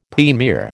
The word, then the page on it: premier: American English